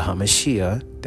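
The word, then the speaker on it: HaMashiach (המשיח): So the fulfillment already took place with Christ, because the prophets talked about the coming Messiah, the anointed one, the HaMashiach, that's how you say it in Hebrew.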